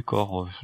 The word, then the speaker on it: Kor: Et si on a regardé la quatrième de couverture, en fait, on sait qu'il s'agit de Kor. Est-ce que j'ai bien prononcé? – Kor. Il est facile, celui-là.